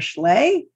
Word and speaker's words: Schley: Um, you can also go to my website, which is spelled the same as my name, Sara Schley, S-A-R-A-N-O-H-S-C-H-L-E-Y dot com.